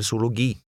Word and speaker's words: zoologi: When a Z is used, it sounds like an S. Read number 7, which has a hard G in the last word. – Sønnen min studerer zoologi.